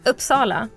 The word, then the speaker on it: Uppsala: Uppsala.